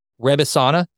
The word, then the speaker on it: Rebesana: And here's the path that I think it makes sense for us to travel, because I do want to talk about Rebisana. Did I pronounce that right? – You did.